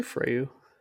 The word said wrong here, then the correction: Frau: What did I say? Well, what's your name? Frau. – Frau.